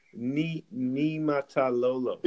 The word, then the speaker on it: Niumatalolo: but Tui Maololo, I think. – Yeah, it was Ken Niumatalolo. – Yeah, Niumatalolo.